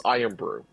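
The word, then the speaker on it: Irn-Bru: It is spelled IRN hyphen BRU. – Okay. – But I think it's pronounced Iron Brew.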